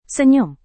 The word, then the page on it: senyum: indonesian